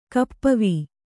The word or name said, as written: ಕಪ್ಪವೀ